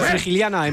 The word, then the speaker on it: Frigiliana: Bueno, confirmamos, Frigiliana en Málaga, sí. – Sí, yo lo he dicho bien, lo he dicho bien.